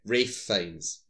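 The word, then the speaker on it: Ralph Fiennes: Rafe Fines. – Yeah. – Spelled Ralph, but it's pronounced Rafe.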